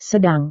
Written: sedang

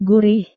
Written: gurih

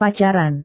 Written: pacaran

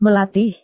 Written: melatih

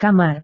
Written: kamar

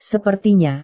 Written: sepertinya

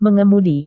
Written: mengemudi

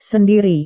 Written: sendiri

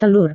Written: telur

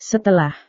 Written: setelah

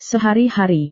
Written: sehari-hari

